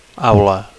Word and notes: aula: Catalan

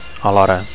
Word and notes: alhora: Catalan

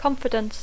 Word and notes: confidence: English

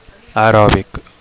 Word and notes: aerobic: Catalan

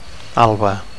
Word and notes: alba: Catalan

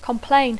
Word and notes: complain: English

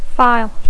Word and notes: file: English

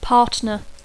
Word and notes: partner: English

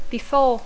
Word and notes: before: English

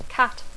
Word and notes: cat: English

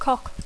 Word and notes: cock: English

